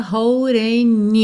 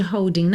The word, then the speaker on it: holding: Aqui, o D ficou rarara, holding you. O G de holding não é pronunciado? Juntamos pelo N, holding you, holding you.